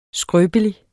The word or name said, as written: skrøbelig